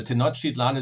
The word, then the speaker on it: Tenochtitlan: Um, before we get started, is Tenochtitlan. That's how we pronounce that, correct? – Well, actually, nobody knows uh, exactly how it was pronounced, so don't worry about it. And uh, uh of course, uh, Tenochtitlan is quite good.